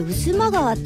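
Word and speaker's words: 巴波川: 日帰りお出かけ、日帰りで行くとしたら栃木県の栃木市の市内の中心部を流れる渦間川、これ渦間川って読むんだ、振り仮名ありがとうございます。春の風物詩、渦間の鯉のぼりですかね、渦間川沿いに掲揚されます。いい鯉とかけて 1151 匹いい鯉の、おお、鯉のぼりが渦間川を彩りますということでね、写真もつけていただいてるんですけど、これは圧巻ですね。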